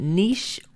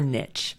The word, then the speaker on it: niche: By the way, you can pronounce it niche or niche. Even though it's a French word and I speak French, I learned the term from an anthropology professor who pronounced it niche, so that's how I pronounce it.